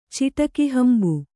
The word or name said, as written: ಚಿಟಕಿಹಂಬು